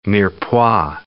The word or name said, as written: mirepoix